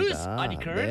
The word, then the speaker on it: loose articles: 네, 발음 조심하세요. 혼납니다. – 네, loose articles가 아니고 loose articles입니다. – loose articles.